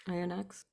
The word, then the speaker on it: Oyonnax: Then they're away at, I don't know how to say it, Oyonnax, French club. – Mm-hmm. Oyonnax.